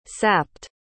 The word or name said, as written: sapped